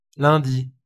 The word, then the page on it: lundi: France (Ouest); [lɛ̃.di]; France (Avignon); [lœ̃ⁿ.d͡zi]; /lœ̃.di/